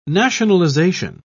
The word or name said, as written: nationalization